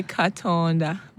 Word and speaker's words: Katonda: So Katunda Mulungi? Is that how you say it? – Katonda. Or am I it's Katonda. Supposed to be Katonda. Mulungi. – Mulungi. – Not Katunda. Katunda is passion fruit.